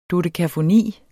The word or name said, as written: dodekafoni